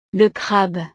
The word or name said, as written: le crabe